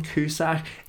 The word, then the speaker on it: Cusack: Cusack. No H. No H. – John Cusack.